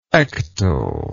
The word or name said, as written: ectal